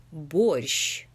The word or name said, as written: Borscht